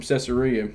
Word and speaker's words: Caesarea: At that very moment, three men stood before the house where I was having been sent to me from Caesarea. Did I say that right? – Yeah. – Y'all say Caesarea like that from... – Mm-hmm. We do. Okay.